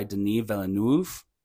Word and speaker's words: Denis Villeneuve: This movie is directed by Denis Villeneuve. Probably said that wrong. – No, you nailed it with the uh, with the.